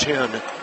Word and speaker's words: Thien: It's my understanding Grace Tien, spelled T-H-I-E-N, pronounced Tien, is second in the MAC in shooting threes.